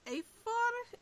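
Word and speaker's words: Eyþór: I'll let you off, because I think it's pronounced Eithor, I think, something like that. – Oh, Eithor, yes, Eithoringi, that's it.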